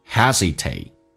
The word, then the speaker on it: hesitate: Hesitate 这个发音。嘴巴打开一点点，吸气，收小腹，hesitate， do not hesitate， hesitate 的 t 不发音，略音，hesitate to tell them，看一下 tell，打开口腔，哎，下巴往下掉一点点，舌尖抵住上齿龈，来体验一下，all， tell， tell them， do not hesitate to tell them， they will be。